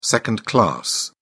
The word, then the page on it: second class: British English